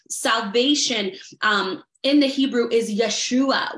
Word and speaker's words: Yeshua: Yeshua without the H at the end. But either way, pronounced the same way.